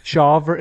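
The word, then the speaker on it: Shawver: AJ Smith-Sauver? – Sure. – Yeah. – I'm going to rely on your pronunciation. – Shaver. It's S-H-A-W-V-E-R.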